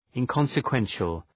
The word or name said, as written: inconsequential